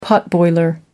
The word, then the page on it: potboiler: American English